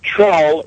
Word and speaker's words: Trull: Trull rhymes with dull. – T-R-U-L-L? – That's correct.